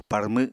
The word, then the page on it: parmw: Apatani